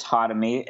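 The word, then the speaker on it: Khatami: So, my name is Chris Hotamy, and I've been saying it like that because that's how you say it.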